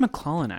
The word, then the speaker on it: MacLachlan: I think it's MacLachlan actually. I think that's how I heard Laura Dern perform- pronounce it in an interview once.